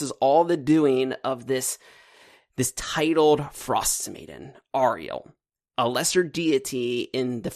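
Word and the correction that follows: Auril: Fun fact, it's actually Oriel. – Oriel. That's a good point. – According to the pronunciation guide, they put one in here. Good for them. – Yeah, it's O-H-R-E-E-L.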